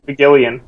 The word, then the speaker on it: McGillion: Paul McGillan. McGillion. McGillion. Okay, I wasn't sure to, if I needed to pronounce the ex the uh, the other I or not.